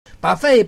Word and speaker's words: buffet: Buffet, buffet, buffet, 字尾不发音，在法语的念 buffet。